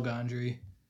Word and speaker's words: Gondry: Written by Charlie Kaufman, directed by Michelle Gondry. Not Michael Gondry, as I said in the episode. I mispronounced it, but there you go.